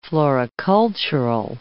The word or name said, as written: floricultural